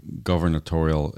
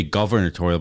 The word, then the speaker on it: gubernatorial: And everyone's telling me, no, it's actually pronounced gubernatorial. – That's in the comments of the YouTube. Well, thank you very much for that, for pointing that out to me.